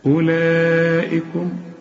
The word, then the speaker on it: أولئكم: ما انت فتح المصحف وماشي معايا، الواو عليها خمسة، خمسة مدورة كده، اسم حرف علة يكتب ولا ينطق، ثلاث حروف إذا لقيت عليهم الخمسة المدورة دي الدوران ده الغيهم من النطق. إيه هم؟ الألف والواو والياء، تلاقيهم زي دي. أولا ما اوعى تقول لي أولئك، لا أولئكم. وعندك مد أربع حركات، إحنا قلنا عليه مد واجب زي ما قلنا إيه جاء، هنا برضو أولئكم، والهمزة هنا مكسورة.